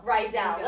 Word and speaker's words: Riedel: Riedel. Like Riedel High and Dani Zuko went to Riedel High.